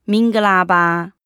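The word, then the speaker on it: 明 格 拉 巴 (မင်္ဂလာပါ): 其实非常简单哦，叫做明格拉巴，它就是你好的意思。发音听起来是不是很像中文的“ 鸣个喇叭” 呢？ – 鸣个喇叭，好厉害！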